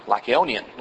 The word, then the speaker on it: Lycaonian: Now when the people saw what Paul had done, they raised their voices, saying in the Lycaonian language, and I'm mispronouncing this according to it, it's Lycaonian, Lycaonian language.